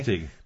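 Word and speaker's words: Assateague: On Astegua, how do you say that? Astetig Island. – Yeah, okay. Astetig.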